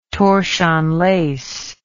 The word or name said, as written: torchon lace